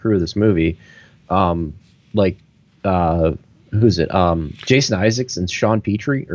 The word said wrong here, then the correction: Pertwee: or it's Pertwee. Pertwee? Yeah.